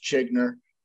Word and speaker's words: Zschiegner: Okay, so my last name is pronounced Zschiegner. Those Z and the S are silent.